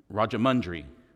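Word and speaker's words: Rajahmundry: And so this week we are brought to Rajamundry. I think I said that right. Did I say that right? Rajamundry, okay, and Pastor Kumar.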